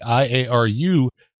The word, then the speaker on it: IARU: So next weekend, we have the IARU. I said that right, right? Yeah. Okay.